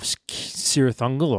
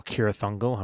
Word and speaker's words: Cirith Ungol: We cut back to the Tower of Cirith Ungol, or Cirith Ungol, however you want to pronounce it.